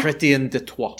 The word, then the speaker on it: Chrétien de Troyes: Chrétien de Troyes. – Troyes. Yeah. You've got to simultaneously, like, you've got to, like, roll the R a little bit. – Okay. Chrétien de Troyes.